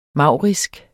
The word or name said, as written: maurisk